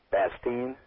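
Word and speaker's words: Bastien: Red Bastion. – Bastine. – Bastine. – Yes. – I'm trying to get the pronunciation correct. – Yes, well, that's the correct.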